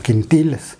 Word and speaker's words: Quintiles: La empresa en ese momento se llamaba, bueno, yo lo leí como quintiles. O dice que, se, bueno, se, en inglés se pronuncia Quintiles Quintiles era una empresa de una CRO muy grande que eventualmente se volvió lo que hoy es IQVIA.